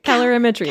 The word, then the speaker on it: calorimetry: So it's either direct or indirect calorimetry. Did I say it right? – No. – No, say it for me. – Calorimetry. – Calorimetry.